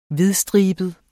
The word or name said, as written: hvidstribet